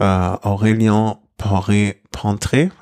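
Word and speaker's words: Aurélien Paret-Peintre: Aurelien Poré Pentré, habe ich das richtig ausgesprochen oder zumindest im Ansatz richtig? Ja, sehr gut.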